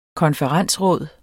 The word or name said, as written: konferensråd